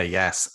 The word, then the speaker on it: Yass: You grew up in Yass, New South Wales. Did I pronounce that right? – You did, yeah, Yass.